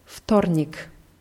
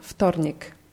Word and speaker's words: Вторник: Dienstag heißt Вторник. – Вторник. – Ja, wird vorne mit W, T geschrieben, aber W wird wie F auf, ausgesprochen. Вторник.